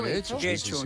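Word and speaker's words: Getxo: En Guecho, que nunca sé cómo se pronuncia. – ¿Guecho? – Guecho. Yo creo que lo pronunció bien.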